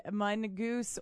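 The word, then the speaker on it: mongoose: Said mungoose, or, well, like it's spelled M-U-N, mungoose, yeah. Uh-huh. Or the plural form is mongooses, uh-huh. Or rarely mongeese.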